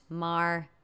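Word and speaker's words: Maher: that's M-A-H-E-R, but pronounced Mar.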